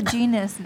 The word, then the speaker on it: genus: Or is that like the genus name? I think that's the genus name. – Yeah, that's the genius name. – Genius name? – That's the genus name.